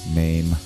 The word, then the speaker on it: Mame: Is a Broadway actress and has been down here was down here like a month ago doing uh, play a musical Mame. Every time I say that, I think maim, like rend somebody limb from limb, but that's not how it's spelled. It's M A M E.